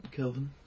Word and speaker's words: Kelvin: Calvin Hoffler, dude's gnarly. – Calvin Hoffler. I think that's how you say it. – Kelvin. – K-E-L-V-I-N-H-O-E-F-L-E-R.